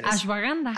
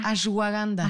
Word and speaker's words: ashwagandha: Ashwagandha. Ashwagandha.